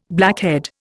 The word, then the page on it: blackhead: Indian